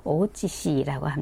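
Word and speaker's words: 오치시 (大内氏): 여기에 나오는 대내전은 이게 일본은 이제 큰 가문이 지방의 봉건 영주 그러니까 작은 소왕국처럼 되어 있는데, 어, 큰 대자다, 안쪽이라고 하는 내자 써서 대내라고 써서 일본 발음으로 오치시라고 합니다.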